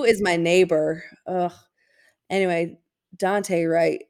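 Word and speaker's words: Daunte: W-R-I-T. – No, you just said Danute. His name is Dante Wright. – Dante.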